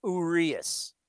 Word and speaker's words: Urias: And it is Urias. You're going to hear so many different people butcher that pronunciation. But it's Urias.